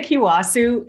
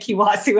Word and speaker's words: Kiawesu: It's a really tough last name. If you try to read it on your own, you probably wouldn't get Kiawesu out of it. There's like a silent A in there.